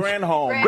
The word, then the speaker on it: Granholm: Grantholm just proved it. China, it's like Granholm. – Granholm. – It's Granholm. Granholm. Pronounce it correctly, Greg.